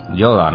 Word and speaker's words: Yoda: Maestro Yoda, ¿no? – Sí. – Estaría bien dicho.